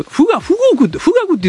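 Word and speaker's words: 富嶽: その、私は子供の頃、そういう、なんかあの、日本の海外の戦闘機とかよく雑誌コーダー載ってて、はい、で、日本は富岳を、富岳って言ってたと思うよ、あれ。 – あ、富岳と書いて富岳と読みます、ね。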